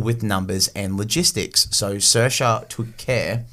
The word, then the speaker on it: Saoirse: Her name was Saoirse. S-A-O-I-R-S-E. – S-A-O. – I-R-S-E. – Thank you. – Because it's spelled in the Irish way.